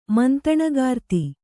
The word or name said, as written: ಮಂತಣಗಾರ್ತಿ